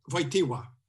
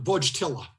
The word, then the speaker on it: Wojtyła: This is what John Paul II, when he got his doctoral thesis as, see if I can pronounce it, Karol Wojtyła. I think that's it. It's not Wojtyla.